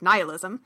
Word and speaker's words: nihilism: nihilism, or as some people pronounce it, nihilism, and asked if we'd do an episode on it, and we said, sure, whatever, nothing matters anyway, so why not? – Exactly.